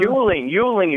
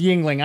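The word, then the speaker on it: Yuengling: Oh, Yingling, you say, right? – Correct. Yeah. I've heard it pronounced Yingling.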